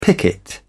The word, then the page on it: picket: British English